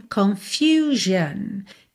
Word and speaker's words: confusion: Já na segunda, confusão, confusion. A letra U tem esse som U, tá?